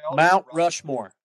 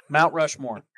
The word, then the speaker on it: Mount Rushmore: Why are you throwing an extra syllable in there? – Big words. Anyways, Mount Rushmore. Mount Rushmore. – Much better.